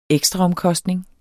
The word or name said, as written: ekstraomkostning